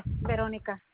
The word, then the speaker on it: Beronica: My first name is B-E-R-O-N-I-C-A. – So that's B-E-R-O-N-I-C-A? – Yes, Veronica.